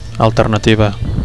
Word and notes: alternativa: Catalan

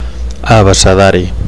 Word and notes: abecedari: Catalan

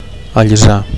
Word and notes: allisar: Catalan

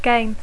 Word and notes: game: English

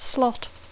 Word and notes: slot: Catalan speaker; intermed

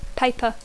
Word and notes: paper: English

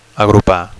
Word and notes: agrupar: Catalan

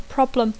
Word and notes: problem: English

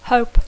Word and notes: hope: English

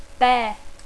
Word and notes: bear: English

